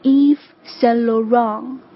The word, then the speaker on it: Yves Saint Laurent: 圣罗兰圣罗兰，正确的发音应该是 Yves Saint Laurent。